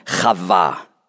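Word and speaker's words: Chava (חוה): gibt der Mensch seiner Frau den Namen Eva. Auf Hebräisch ausgesprochen Chava.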